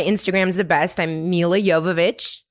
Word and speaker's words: Jovovich: Like Jovovich, but Jovovich. It's pronounced yeah, like yawn. – Yeah. Yawn. Milla Yawn Vovich. That's what people remember you. – It's like silent J.